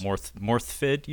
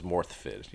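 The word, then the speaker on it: Morfydd: Morthfid, you said, is how you pronounce her name, right? – That's how I heard her say it, was Morthfid, yes. – Okay, good on you for actually checking, because if you look at it, folks at home, it's spelt Morphid or Morphide.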